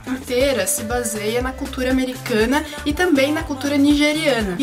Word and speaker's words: Americanah: Porque a personagem principal também diz que o americana, a última sílaba é pronunciada assim, extensamente.